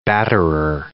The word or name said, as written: batterer